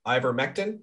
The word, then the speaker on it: Ivermectin: Ivermectin, am I pronouncing that right? – Yes.